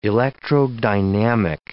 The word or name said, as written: electrodynamic